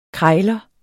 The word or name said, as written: krejler